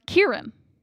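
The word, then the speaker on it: Ciarán: His name is pronounced Kieran. – Kieran, okay. – Like Kieran and Kieran Culkin.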